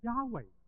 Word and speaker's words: Yahweh: 因为后来我们再研究的时候，其实整个发音应该是 Yahweh，是 Yahweh 才是那个发音，但是犹太人因为基于严守这个诫命，不可妄称神的名字的时候， Yahweh，你会问他，哎， Yahweh 啱唔啱？